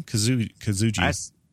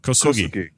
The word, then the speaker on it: Kosugi: Kosugi. – Would you say? Call him Cho. – Kosugi.